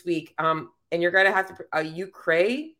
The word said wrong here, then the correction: Euchre: tournament. Is that right? Am I saying that right? – Euchre.